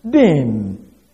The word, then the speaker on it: din: The word "punish" is translated from the Hebrew word "din." Can spell it either D-I-N or D-I-Y-N, din.